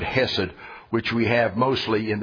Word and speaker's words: hesed: You remember we said the most important word in the New Testament, hesed, C-H-E-S-E-D or H-E-S-E-D. It all depends on whether I'm feeling American or German as to how I pronounce that word.